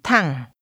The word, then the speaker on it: Thẳng: Hãy đi thẳng. Thẳng. Thẳng. – Straight ahead is pronounced with a falling, rising tone.